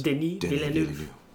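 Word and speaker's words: Denis Villeneuve: Denny Villeneuve.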